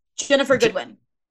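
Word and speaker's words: Ginnifer Goodwin: Yes, Jennifer Goodwin.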